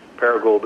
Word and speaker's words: Paragould: This is also an MFA project, and again, it surrounds the co-op's biomass conversion facility in Paragould, Arkansas. I think I'm pronouncing that right. – I think that is exactly the right way to pronounce that.